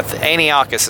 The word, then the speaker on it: Antiochus: I have this nagging feeling that I'm mispronouncing his name. That Antiochus is right.